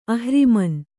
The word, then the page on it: ಅಹ್ರಿಮನ್: kannada